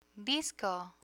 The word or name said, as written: disco